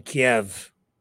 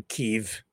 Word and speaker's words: Kyiv: Kiev, or Kyiv, depending on how you view Ukraine, which way you choose to pronounce it.